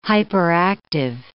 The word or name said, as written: hyperactive